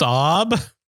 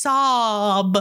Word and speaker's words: Saab: It's pronounced Saab. – Oh, sorry. It's Saab.